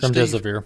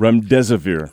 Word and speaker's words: Remdesivir: I think we ought to take the standpoint, look at this, how'd you pronounce it, Remdesivir. – Remdesivir.